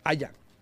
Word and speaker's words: Aya: Aya. – ¿Aya? – Aya. – Yo siempre digo Aiga. – No digas Aiga. Porque se molesta la gente.